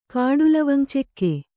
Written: ಕಾಡುಲವಂಗಚೆಕ್ಕೆ

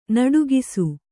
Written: ನಡುಗಿಸು

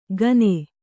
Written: ಖಾನೆ

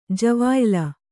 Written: ಜವಾಯಿಲ

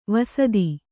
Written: ವಸ್ತ

